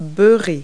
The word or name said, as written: butt